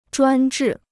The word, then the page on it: 专制: zhuān zhì